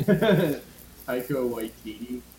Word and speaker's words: Taika Waititi: Takiti Wakati, whatever his name. What's his name? – Taika Waititi. – Oh, I like whatever you just said.